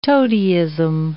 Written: toadyism

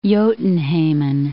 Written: Jotunheimen

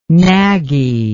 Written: naggy

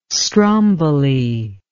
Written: Stromboli

